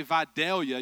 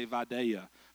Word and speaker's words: Vidalia: But if you're from there, you don't say Vidalia, you say Vidalia, because you talk slow and hear different.